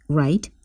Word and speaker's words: write: T is silent, write. W is silent, high.